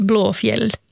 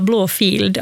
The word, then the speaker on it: Blåfield: Det som folk brukar tendera att uttala Blåfield, alltså blå, alltså en F-I-E-L-D. Ja. Alltså en gammal stavning av ordet fjäll. Så det namnet är Blåfjäll.